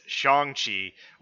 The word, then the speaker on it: Shang-Chi: has stated the official pronunciation of how it's supposed to be said, and apparently it is Shang Chi, which I mean, going to Ong is not too far of a leap, honestly.